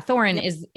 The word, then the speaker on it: Thorin: And Thorin, am I saying that right? Yeah, Thorin is, I was like, suddenly I don't know my own fandom.